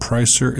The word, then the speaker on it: Preisser: Leslie Pricer was killed Saturday night in LeClaire, and Pricer is spelled P-R-E-I-S-S-E-R.